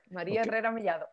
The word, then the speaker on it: María Herrera Mellado: María Herrera Mellado.